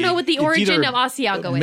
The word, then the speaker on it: Asiago: Asiago.